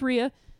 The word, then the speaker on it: Rhea: You know, people ask me, how is it Rhea or Rhea all the time? And I'm like, it's Rhea.